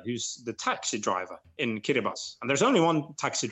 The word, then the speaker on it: Kiribati: And you see Kiribati, for instance, it's actually spelled K-I-R-I-B-A-T-I, but it's pronounced Kiribati. So T-I in Kiribati is pronounced as S.